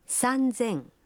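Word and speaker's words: Санзен (三千): Но и здесь произношение меняется. 3000 будет звучать как... – Санзен.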